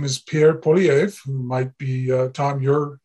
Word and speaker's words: Poilievre: Pierre Poilievre is how he pronounces it.